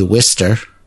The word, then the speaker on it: Worcester: Like there's a town that's spelled Worcester or ER? – ER. – And it we say Worcester.